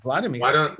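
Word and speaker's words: Wladimir: The W is like pronounced like a V, Vladimir.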